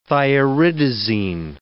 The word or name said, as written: thioridazine